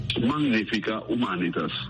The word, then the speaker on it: Magnifica Humanitas: En latín se pronuncia Magnifica Humanitas.